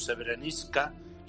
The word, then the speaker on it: Srebrenica: Pues se metió entre los Balcanes y en concreto Srebrenica, o como dicen ellos, Srebrenica.